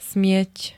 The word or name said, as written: smieť